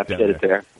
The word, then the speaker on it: pied-à-terre: It'd be good to have, if you could just have like a little like pied-à-terre. How do you pronounce that? You know, just like a little chef. – Yeah, pied-à-terre.